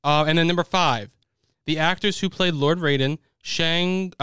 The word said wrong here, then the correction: Shang Tsung: is that how you pronounce the guy's name? – Shang Tsung.